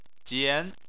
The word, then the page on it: jian: 2